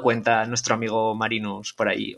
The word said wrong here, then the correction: Marinus: Se pronuncia Marenus, que siempre lo he pronunciado mal, hasta hace poco ah. que he descubierto que se llama Marenus en realidad, porque es holandés y tiene esa ah. pronunciación.